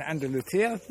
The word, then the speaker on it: Andalusia: But you tell me that you actually live in Andalusia.